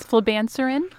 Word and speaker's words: flibanserin: Yeah, it's flibanserin, very sexy name.